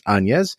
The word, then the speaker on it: Agnès: And I did look up the pronunciation. It's Agnès.